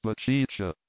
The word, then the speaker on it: Baticcia: In consequence of this connection of St. John with the city, great numbers of the common people are christened Giovanni Baptista, which latter name is pronounced in the Genese patois Baticcia, apostrophe like a sneeze.